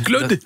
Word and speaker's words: Claude: Claude. – Dat spreek je op zijn Frans uit.